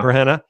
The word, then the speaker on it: Burhenne: Am I saying that right? Burhenne? – That's great. Yeah. – Burhenne.